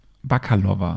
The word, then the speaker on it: Bakalova: Sie die Hündin wird gesprochen von Maria Bakalova. Okay. Sie ist eine bulgarische Schauspielerin. Sie ist seit 2017 im Business und Maria die meisten. Bar Barkalova heißt sie? – Bakalova. Okay. Ich weiß nicht, wie sie richtig ausgesprochen wird.